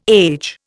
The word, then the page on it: age: Indian